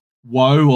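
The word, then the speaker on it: Wa: which get called the lands of Wo, or spelt W-A, but pronounced, I think, more like Wo,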